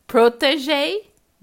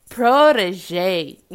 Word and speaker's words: Protégé: É porque a, o americano dobra o T, né? Protegei e o britânico protegei. Protegei. É, vamos colocar a pronúncia lenta americana. Vamos lá. Protégé.